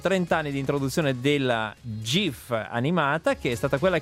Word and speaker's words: GIF: C'è chi dice GIF, c'è chi dice GIF, e allora Steve Wilhite, che è il creatore di questo formato, dice che si pronuncia GIF. – GIF. Oh. – Però perché sta per Graphic Interchange Format, quindi dovrebbe essere graphic.